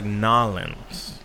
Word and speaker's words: New Orleans: I'm sorry, I've never heard it pronounced like that. – How? – New Orleans? – Yeah. – Hmm. – So I was like Nolens. – So some people down there do say something like Nolens.